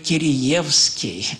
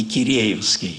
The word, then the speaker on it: Киреевский: «у нас принято говорить Кириевский, а не Киреевский».